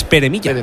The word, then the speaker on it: Pere Milla: Pera Milla o vamos a decir la que también puede ser Limón Milla, porque es Pere Milla.